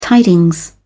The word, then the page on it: tidings: British English